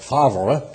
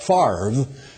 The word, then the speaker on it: Favre: Now, you know, that they don't know how to pronounce Brett Favre either. They call him Brett Favre. and they put the R in front of the V, which is nonsensical, but they do it.